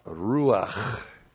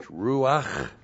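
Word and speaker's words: ruach (רוח): He's pouring out, and he's pouring out the first person singular of the noun ruach. R long U A C H, ruach, is spirit, first person singular suffix, my spirit, God the Holy Spirit.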